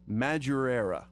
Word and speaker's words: Madureira: Now, how do you pronounce it? – Madureira.